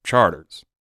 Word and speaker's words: Chartres: And I have to tell you, if people look this up, they're going to think I'm crazy because it's spelled C-H-A-R-T-R-E-S. And people say Chartres and all this stuff. But if you're from the city, if you're from New Orleans, we call it Charters.